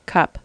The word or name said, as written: cup